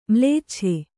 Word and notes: ಮ್ಲೇಚ್ಛ: mlēccha

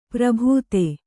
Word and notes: ಪ್ರಭುತೆ: prabhute

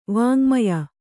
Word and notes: ವಾಙ್ಮಯ: vāŋmaya

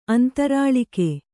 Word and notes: ಅಂತರ್ಲಾಗ: antarlāga

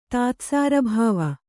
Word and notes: ತಾತ್ಸಾರಭಾವ: kannada

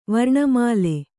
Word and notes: ವರ್ಣಮಾಲೆ: varṇa māle

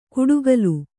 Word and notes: ಕುಡುಗಲು: kuḍugalu

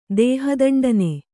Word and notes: ದೇಹದಂಡನೆ: dēha daṇḍane; Kannada

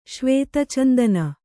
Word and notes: ಶ್ವೇತಚಂದನ: Kannada

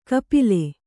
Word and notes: ಖಪಲಿ: Kannada

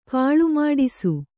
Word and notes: ಕೋಲಮಾಡಿಸು: kōlamāḍisu; dial.

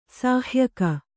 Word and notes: ಸಹಾಯಕ: sahāyaka

